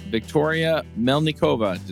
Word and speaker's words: Victoria Melnikova: And this week we have a special guest, Victoria Melnikova.